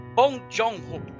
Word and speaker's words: Bong Joon-ho: Tal de Bong Joon-ho. Falei certo, Marcelo? – Olha, depois do meu treinamento em coreano, eu diria que você brilhou.